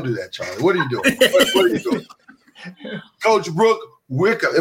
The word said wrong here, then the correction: Wyckoff: Am I saying it right? – Wyckoff. – I knew I was going to mess it up. I knew it. I was like, it's going to be Wyckoff, but I'm going to say Wickham.